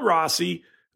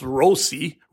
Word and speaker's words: Rossi: So he was called Rossi. Then he told press late in the year, actually, it's pronounced Rossi. Roll that R, Rossi.